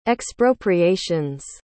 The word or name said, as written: expropriations